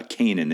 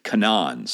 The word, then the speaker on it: Canaan: the land of Canaan which incidentally we learned we've been pronouncing incorrectly uh, all this time. Uh, uh, the correct pronunciation is not uh, Canaan; it is Canaan.